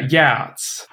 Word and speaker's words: Geats: Spelled G-E-A-T. – I say the Geats in my head, but that sounds like I'm not saying it right. – A lot of people say the Geats. – Okay. Geats? – Yeah, Geats.